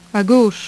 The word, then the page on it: a gauche: French